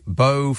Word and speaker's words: Bow: pronounced like Bow from Bow and Arrow?